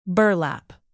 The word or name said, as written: burlap